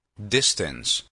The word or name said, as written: distance